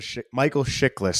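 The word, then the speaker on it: Chiklis: Yeah, I mean, you got Michael Shilkes playing Red Auerbach, and he's not even in the show that often. So, or Michael Shiklis, sorry, said his name wrong.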